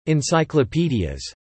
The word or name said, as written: encyclopedias